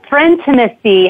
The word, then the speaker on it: Frintimacy: I'm going to ask you, because I didn't have time right before we started, and I don't want to pronounce the name of your book incorrectly. So pronounce it. So will you tell it to us? – Yes, it's Frintimacy, How to Deepen Friendships for Lifelong Health and Happiness.